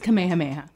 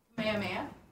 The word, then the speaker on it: Kamehameha: It's just Kamehameha. – Thank you. What did I say? Kamehameha. I put too many syllables in the Kameh. Say it again for me. – Kamehameha.